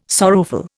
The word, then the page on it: sorrowful: Indian